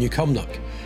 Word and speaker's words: New Cumnock: Ian, I've got to say as well, well done on the pronunciation of New Cumnock. Some people go New Cumnock. You put the two together, New Cumnock. It is one word, but it's two.